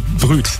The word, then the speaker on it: Brut: Das heißt nicht Brut, ne, sondern Brüt. Naja, gut.